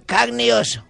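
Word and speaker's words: Carne e osso: Se pronuncia carne y oso. Carne y oso. ¿Oso? – Sí, que significa carne y hueso.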